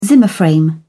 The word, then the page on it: zimmer frame: British English